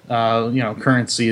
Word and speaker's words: CurrentC: CurrentC is the way they want you to pronounce it.